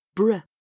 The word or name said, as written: br